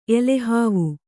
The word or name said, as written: ಎಲೆಹಾವು